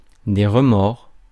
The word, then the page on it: remords: France; [ʁə.mɔʁ]